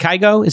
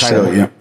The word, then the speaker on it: Kygo: I was, you know, when we went to see Kygo, is it Kygo? Is that how you pronounce his name? – I think Kygo. Kygo's amazing.